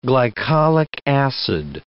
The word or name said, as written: glycolic acid